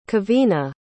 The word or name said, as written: kevina